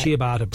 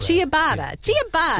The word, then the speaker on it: Ciabatta: Ciabatta bread. – Ciabatta.